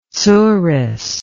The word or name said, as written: tsuris